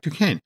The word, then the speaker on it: Duquesne: I mean, for example, Tony Dalton's Jack Dutanesque, is that how we pronounce his name? – Jack Duquesne.